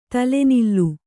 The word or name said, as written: ತಲೆನಿಲ್ಲು